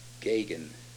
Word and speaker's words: Geoghegan: Her name is Celia, C-E-L-I-A, Gagan. It's spelled G-E-O-G-H-E-G-A-N, but it's pronounced G-A-G-I-N. It's an Irish name. G-E-O-G-H-E-G-A-N is Gagan.